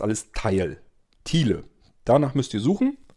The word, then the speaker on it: Tile: Zunächst mal, es gibt also den Skill, der nennt sich natürlich auch Teil. Teil schreibt man Thiele, T-I-L-E.